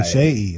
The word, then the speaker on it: Seyi: We'll get the S-H-E-Y-I, right? – S-E-Y-I. – Oh, Shea, okay. – So it's pronounced like Shea Gilgeous Alexander.